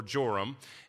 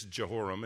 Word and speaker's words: Jehoram: Now, if you're reading the NIV, it says Joram. So it's the same name, but it can be translated Jehoram or Joram. And I'll just pronounce it with the ESV, which is Jehoram.